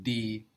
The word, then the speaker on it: die: The same as in English, but spelled H-I-E-R. I-E in German gives us E, like a long E, like in D, the word for the, spelled D-I-E. And E-I gives us I, as in bleiben, which is B-L-E-I-B-E-N.